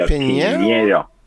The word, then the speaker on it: Pineiro: It's a N-A sound, if you can manage that. So it's a Pineiro.